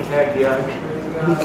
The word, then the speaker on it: Haggai: Let's go to Haggai. Did I pronounce that right, Pastor? – Haggai.